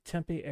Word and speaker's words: Tempe: Tempe, Arizona.